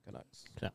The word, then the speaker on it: Canucks: Canucks.